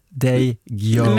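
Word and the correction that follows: Ndegeocello: Nej, Ngeg-Ocello. Ngeg-Ocello, ja. Kanske.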